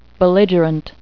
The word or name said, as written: belligerent